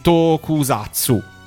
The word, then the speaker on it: Tokusatsu: Tokusatsu.